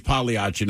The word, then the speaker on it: Pagliacci: So one person says, hey, man, the G is silent in Pagliacci. – I know that. – Another one says it's Pagliacci, not Pagliacci.